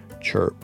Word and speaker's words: CHIRP: that's called CHIRP, Christ Renews His Parish, C R H P. They pronounce it CHIRP.